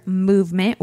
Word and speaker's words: MVMT: We would like to thank our sponsor, Movement Watches. That's MVMT. They spell it cool because who needs vowels, right? – That's right.